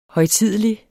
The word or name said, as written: højtidelig